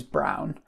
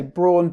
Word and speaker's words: Braun: It's sort of, the German pronunciation is Braun. But okay. In English, or it's, you know, most people say Braun,